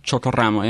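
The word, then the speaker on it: Chocoramo: And I remember one time there was a product from Colombia named Chotorramo. And it's like this cake slice. – Yep. Chotorramo? – Chotorramo, yeah. Roll your R. Ramo.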